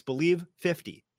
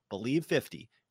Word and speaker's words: BLEAV50: It's BELIEVE50, and it's spelled a little funky, but that's okay. You're going to get it. BLEAV50, BELIEVE50, to receive your 50% welcome bonus on your first deposit.